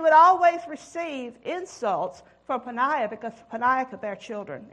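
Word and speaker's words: Peninnah: And one of them was named this Peninah. And it does come from the name Penny. Peninah. Well, that's pretty easy to pronounce. Peninah. It don't look like it's pronounced that way.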